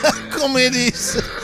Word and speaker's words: Archimedes: Archimedes. – Archimedes.